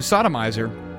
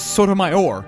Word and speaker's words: Sotomayor: Because I always used to pronounce her name Sodomizer. – Mm-hmm. – But then I learned it was Sotomayor.